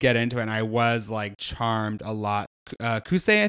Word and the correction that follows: Kosei: I think his name is. Um, am I saying his name right? Yeah, Kosei. Kosei.